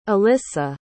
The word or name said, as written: ilissa